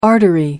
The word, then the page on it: artery: American English